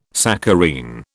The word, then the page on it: saccharine: British English